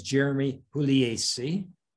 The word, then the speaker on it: Puglisi: With me today is Jeremy Puglisi the proper Italian pronunciation, I might add, uh, from New Jersey.